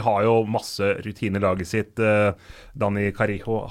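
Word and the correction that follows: Carriço: har, eller Carisot er det vel når det uttales, eh, har flest kamper i Europaligas historie.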